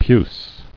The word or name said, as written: Puce